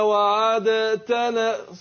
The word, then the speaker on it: وعدتنا: وعدتنا صعب واخد بالك الإدغام جاء للتخفيف فهنا أدغمنا الدال في التاء ربنا وآتنا ما وعدتنا على طول بعد العين بنطق الإيه التاء ماشي تفضل يا عبد الرحمن على بدوية مش كده تفضل.